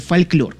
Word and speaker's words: фольклор: Это афроамериканская музыка, основана на такой вот фольклорный, да, правильнее будет сказать, фольклор пишется, но произносится фольклор.